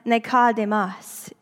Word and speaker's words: Nikodemos: Because his name is Greek, it is actually pronounced Nikodemos.